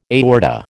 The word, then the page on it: aorta: American English